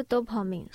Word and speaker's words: Dopamine: 你自己有没有一个自己念这个张专辑的方式？ – 就 Dopamine，是 Dopamine 吗？好，因为我很怕，就是你既然都已经强调，我是不是要念成 Dopamine？